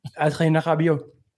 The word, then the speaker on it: Adrien Rabiot: Adrien Rabiot. You got to get the H sounding R. It's a very French thing.